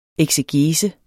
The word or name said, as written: eksegese